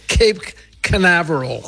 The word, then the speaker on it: Cape Canaveral: il modo corretto di pronunciare Cape Canaveral. Sentiamo. – E si dice, Nino, si dice Cape Canaveral.